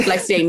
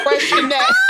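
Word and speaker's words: Freixenet: Girl, first of all, that's pronounced fresh in that.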